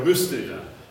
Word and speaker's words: Rüstige: But at the end of the introduction, he signs it, I will be ever yours, Der Rüstige. So it kind of sounds like Rist, but it's spelled differently. It's R-U-Umlaut.